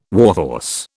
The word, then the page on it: warhorse: British English